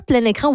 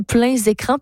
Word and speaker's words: Plein Écran: On peut dire Plein Écran ou pleins écrans parce qu'il y a des S entre parenthèses. Donc, et c'est vraiment, Ariane Roy-Poirier disait, c'est libre. Là. Vous pouvez prononcer plein écran, pleins écrans.